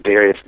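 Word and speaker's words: Dharius: So for me, social media, everything is Darius Daniels. And it's easy because my name is spelled with an H. It's D-H-A-R-I-U-S.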